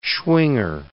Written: Schwinger

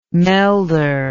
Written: melder

